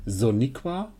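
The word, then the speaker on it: Sonequa: Soniqua.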